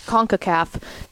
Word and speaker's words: CONCACAF: CONCACAF.